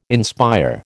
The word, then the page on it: inspire: American English